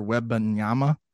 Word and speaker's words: Wembanyama: this Victor Wembanyama. – Oh, yeah. I think that's how it's pronounced, isn't it? – Yeah.